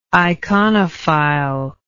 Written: iconophile

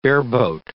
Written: bareboat

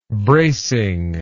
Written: bracingness

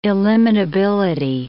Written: illimitability

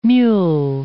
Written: mule

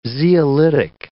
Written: zeolitic

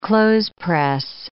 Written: clothespress

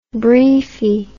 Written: briefie

